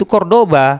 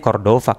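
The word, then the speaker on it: Córdoba: itu tulisnya calat Rava, va pakai v v itu kan ba dalam bahasa eh, dalam ejaan Spanyol Cordova itu Cordoba hmm. Cordova hmm. bukan Cordova Uh, salah orang Indonesia sebutnya Cordova Cordoba maksudnya tulisannya Cordova tapi Cordoba bacanya tetap bukan hmm.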